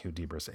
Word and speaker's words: Hudibras: I guess it's pronounced Hudibras, H-U-D-I-B-R-A-S.